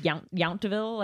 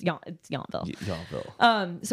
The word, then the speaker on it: Yountville: I think technically it's pronounced Yountville, like okay. Like in Fury, but it's Yountville.